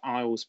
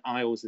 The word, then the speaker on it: Eyles: which will be British Eyles Productions, and Eyles is spelled E Y L E S, as in my surname.